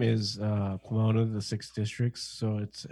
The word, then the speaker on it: Pomona: Where you guys are living in Pomona, right? California? Did I say that right? – Yep.